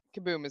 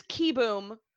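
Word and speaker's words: Kieboom: It's not pronounced Kaboom, but I don't care. – Yeah. – Dusty pronounces it Kaboom, which I am taking as permission to continue to mispronounce it as Kaboom. It is Kiboom for those paying attention at home.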